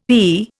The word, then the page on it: HE: Indian